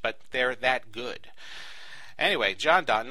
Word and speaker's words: Jondon: One way to do that is by looking into the seminars provided by a company called John Don. It's spelled J-O-N-D-O-N.